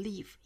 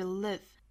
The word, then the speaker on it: live: 这个 live 是松元音哈不要读成 leave 应该是 live It is impossible to live without failing at something.